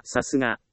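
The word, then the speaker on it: 流石: さすがという漢字はさすがと読みます。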